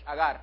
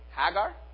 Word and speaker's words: Hagar: I gotta say it in Spanish. Agar. How do you say it in English? Hagar. Hagar.